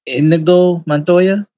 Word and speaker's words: Inigo Montoya: Whereas the Man in Black and Inigo Montoya, did I pronounce that right? – Yep.